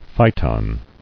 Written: Phyton

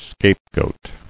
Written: Scapegoat

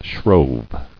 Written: Shrove

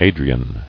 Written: Adrian

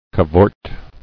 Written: Cavorted

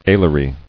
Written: Alary

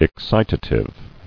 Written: Excitatory

